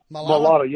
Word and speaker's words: Mailata: Mailata. Is it Mailata? – Mailata, yeah.